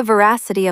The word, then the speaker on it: veracity: When you check the veracity of a story, you're confirming whether it's genuine fact or fake news. Stress the second syllable. V-ra-si-ti. Try saying it. Veracity.